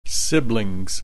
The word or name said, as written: siblings